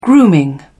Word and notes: grooming: British English

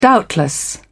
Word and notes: doubtless: British English

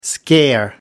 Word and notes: scare: American English

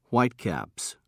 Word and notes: whitecaps: British English

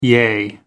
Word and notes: yea: American English

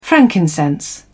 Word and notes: frankincense: British English

